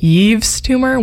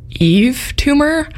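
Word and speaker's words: Yves Tumor: It's spelled Y-V-E-S. And for the longest time, I've pronounced it Eve's tumor, which I just learned is incorrect. And it's just Eve tumor.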